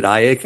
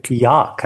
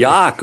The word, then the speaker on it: Iac: Is it Iac? I-A-C? Iac? – Yaak, actually. – Yaak, okay. – So which is a bit of an anachronism on my part, you know, but. – Well, so Yaak, he catches Yaak's eye, and then he pursues him for some time, mm-hmm.